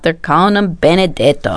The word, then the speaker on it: Benedetto: I, of course, had to call up my mom and say, Mom, how do you say Benedict in Italian? Because they don't call him Benedict. I mean, it's Benedetto.